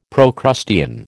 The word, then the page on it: Procrustean: American English